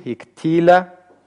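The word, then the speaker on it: Хиктиля (הִקְטִילָה): Хиктальти, хиктальта, хиктальт, хиктиль, хиктиля. Вот особенно на это ударение. Третье лицо, женский род, единственное число. Хиктиля. Ударение у нас сохраняется здесь на втором слоге.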